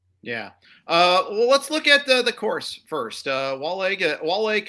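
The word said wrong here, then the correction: Waialae: Country Club. It's, and I butchered the pronunciation. – Wailai.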